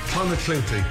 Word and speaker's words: Clonakilty: Clonakilty, thank you.